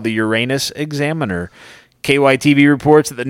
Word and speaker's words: Uranus: town of Uranus, which is pronounced the way any self-respecting class clown would say it.